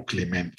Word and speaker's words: Climent: También expuso Jordi Camps Rivera, Enrique Climent, siempre he tenido problemas para ver cómo pronunciar, si Climent o Climent. No veo acento en el Climent, así que prefiero pronunciarlo como Climent.